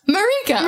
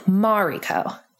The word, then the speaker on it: Mariko: But Eastern names, in Japan, tend to have an emphasis on the first syllable. So Mariko. Instead of Mariko.